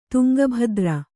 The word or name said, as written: ತುಂಗಭದ್ರೆ